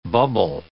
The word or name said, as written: bubble